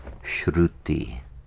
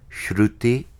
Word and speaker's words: Shruti: Shruti is spelled S-H R-U-T-I. And there's a little dot under the R indicating that it has a one-tap roll. One-tap means Shruti. R, one tap. The tongue taps once.